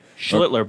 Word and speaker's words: Schlittler: Was there any doubt that Aaron Boone didn't trust Cam Schlittler or Max Fried? – They're better pitchers. – Are they? – Cam Schlittler, you know the guy's name. That guy there. – Schlittler, be careful. – Yeah, Schlittler.